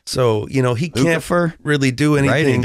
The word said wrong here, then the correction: Lukather: Did I say it right? – Lucifer? – Steve Lukather. – Lukather. Of Toto.